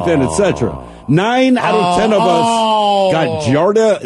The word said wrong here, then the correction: Giardia: Everyone, G-I-A-R-D-I-A. Giardia.